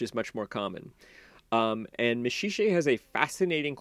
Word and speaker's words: Maxixe: is a boy named Maxixe, which is spelled M-A-X-I-X-E.